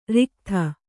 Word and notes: ರಿಕ್ಥ: riktha

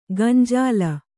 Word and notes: ಗಂಜಾಲ: gañjāla

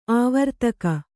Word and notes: ಆವರ್ತಕ: āvartaka

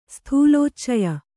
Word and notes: ಸ್ಥೂಲೋಚ್ಚಯ: sthūlōccaya

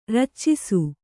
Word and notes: ರಚ್ಚಿಸು: raccisu

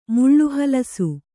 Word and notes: ಮುಳ್ಳುಹಲಸು: Kannada

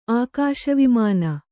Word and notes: ಆಕಾಶವಿಮಾನ: ākāśa vimāna

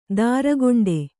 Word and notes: ತರಿಗೆಂಡ: tarigeṇḍa

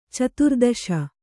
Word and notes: ಚತುರ್ದಶಿ: caturdaśi

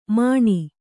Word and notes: ಮಣಿ: Kannada

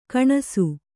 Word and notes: ಗಣೇಸ: gaṇēsa

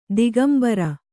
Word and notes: ದಿಗಂಬರಿ: digambari

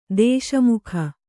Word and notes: ದೇಶಮುಕಿ: dēśa muki; Kannada

